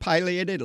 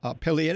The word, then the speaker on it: pileated: And it is, the pronunciation pileated is correct either way. It depends on where you're all from. And it works both ways. I grew up with pileated. A lot of people call them pileated.